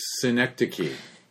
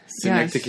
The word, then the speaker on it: Synecdoche: Oh, Synecdoche. – Yes. – Synecdoche, yes.